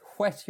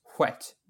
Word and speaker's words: whet: Um, to whet wet your appetite, and I, I suppose in RP it should be pronounced whet with a wh. – Uh, I don't know if it's pronounced like that in RP, but it's certainly. Well, yeah, because because let me see, this pronunciation guide here. I just googled it to make sure I was using the, the right spelling. The pronunciation guide just says wet, but oh, um, okay.